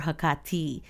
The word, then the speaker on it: Hecate: You know, the pronunciation is muddled a lot of time, and like different people pronounce it different ways, but the right way to say it is emphasizing the ka in the middle. So, you know, it's her ancient name is Hecate or Hecate. So you can say either one, but the he and then ka has to be the big emphasized portion of it, and then the te at the end.